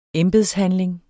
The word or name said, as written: embedshandling